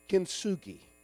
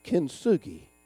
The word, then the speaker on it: Kintsugi: Kintsugi. Say that with me. Kintsugi. I didn't know y'all could speak Japanese. Y'all just had a lesson in Asian language. Kintsugi. Turn to your neighbor and say, Kintsugi.